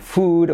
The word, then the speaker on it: food: Sin embargo, esa misma doble O, por alguna extraña razón, en palabras como food o como mood, se pronuncia como una U.